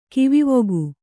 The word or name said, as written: ಕಿವಿವೊಗು